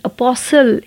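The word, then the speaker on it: apostle: अपोसल यहाँ पे T silent है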